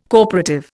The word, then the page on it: corporative: Indian